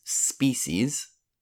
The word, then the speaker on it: species: The word is species, and the pronunciation can be a little bit difficult. Species.